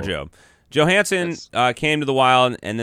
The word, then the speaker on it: Johansson: Here's what it says, the 21-22 NHL pronunciation guide. Marcus Johansson. – Johansson. – Johansson. – But then the announcers were saying Johansson.